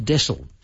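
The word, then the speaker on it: deasil: And someone got in touch with me, and they'd come across this quote that mentioned widdershins, and it's pronounced desil. It's D-E-A-S-I-L, but it's pronounced Desil, and it means clockwise.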